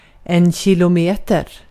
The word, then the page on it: kilometer: /ɕɪl.ʊˈmeːt.ɛr/